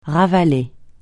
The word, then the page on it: ravaler: francais